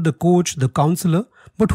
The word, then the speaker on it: Kocs: The village in Hungary is called as K-O-C-S, pronounced as coach.